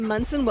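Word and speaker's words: Monson: I am Kim Munson. Welcome to the Kim Munson Show.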